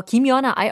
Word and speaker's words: 김연아: Let's take a look at some key terms and expressions from our article, starting with our title, 김유나, 어, 김연아. I always say it wrong, right? 김연아.